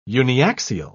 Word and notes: uniaxial: British English

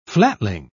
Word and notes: flatling: British English

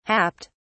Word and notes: happed: British English

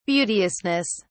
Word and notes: beauteousness: British English

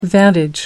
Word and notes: vantage: American English

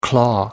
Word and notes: claw: American English

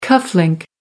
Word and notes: cuff link: British English

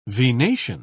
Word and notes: venation: British English

